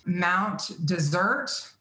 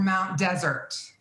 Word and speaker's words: Mount Desert: And then we headed up to Acadia, which is on an island called Mount Desert or Mount Desert. – Okay. – You can pronounce it either way.